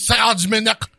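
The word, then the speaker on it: Serge Monast: Now, where he's French, so he might be saying that wrong. It's Serge Manast or some stupid shit.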